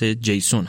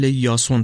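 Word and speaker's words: یاسون (Ιάσων): اما گذشته از اینها، اسم قهرمان اصلی داستان همونطور که از عنوان مشخص جیسون هست. البته توی زبان یونانی ظاهراً به شکل یاسون تلفظ میشه، ولی فکر میکنم همون جیسون رو ما اینجا به کار ببریم بهتر باشه.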